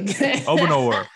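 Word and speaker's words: Obanor: But then you also look at Kevin, did I get his name right? O'Banner. Oh, Banner. There it is. Okay. – Obinor.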